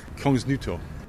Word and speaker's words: Kongens Nytorv: Kongens Nytorv. – Kongens Nytorv? – Kongens Nytorv.